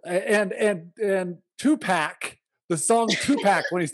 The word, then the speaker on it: Tupac: And they end up talking about, let's hope I say his name right, Tupac. Is that right? – Yes.